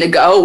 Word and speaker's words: Ngo: But they, everyone basically believed that, it's not Ngo, because I looked up a million pronunciation videos, and the only person that said Ngo was, like, this southern elementary school teacher.